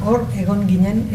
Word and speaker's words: Hor egon ginen: Hor egon ginen. – Ginen es pronuncia, vale. Hor egon ginen, estuvimos ahí.